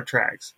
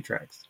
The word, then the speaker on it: Trax: And we spell it T-R-A-X because of I didn't want to take the tracker from Patrick Newman and mimic it, but I wanted it to sound the same, so I spelled it with an X, so it's called Mini Tracks.